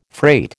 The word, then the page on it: freight: American English